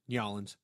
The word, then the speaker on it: New Orleans: It's the proper pronunciation, New Orleans.